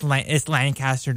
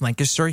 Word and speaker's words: Lancaster: It's a part of me that I just have to correct people on because like they're like it's Lancaster. No, no, that's Lancaster. – That's how you say it in Pennsylvania. It's Lancaster here.